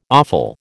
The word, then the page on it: awful: American English